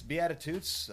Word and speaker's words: Beatitudes: Beatitudes. – Did I say it right? – Yes. – I hope, okay. – Yes. Yes, Beatitudes.